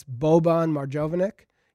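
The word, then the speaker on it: Boban Marjanović: That's Boban Marjanovic. Did I say it wrong? – No, you're right.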